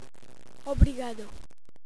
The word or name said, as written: obrigado